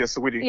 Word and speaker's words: Saweetie: Is it Saweetie? How do you say that artist's name? – Yeah, Saweetie.